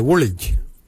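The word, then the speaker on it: WOLIDJ: For calf muscle, we say woolage.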